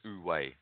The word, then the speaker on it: Wu-Wei: The way you pronounce it is actually Wu-Wei. And, yeah, so oh. – Wu-Wei. So it's usually, yeah, so like O-O-O. But that's how they spell it. W-E-I. So it's Wu-Wei.